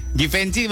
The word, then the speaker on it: Givenchy: Givenchy bacanya apa boy? Givenchy. Givenchy. Givenchy. Givenchy. Givenchy. Ya. Givenchy. Oh, Givenchy bacanya Givenchy.